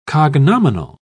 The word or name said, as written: cognominal